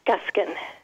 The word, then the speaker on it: Guskin: Sharon Guskin. Is that correct? – That's correct. Yeah, Guskin.